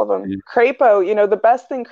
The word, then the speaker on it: Crapo: And so, yeah, Idaho and Mike Crapo in the Senate, perfect name, perfect name from a guy from Idaho because his ideas are going to be crap, but also rhyme with the name of the state, Crapo.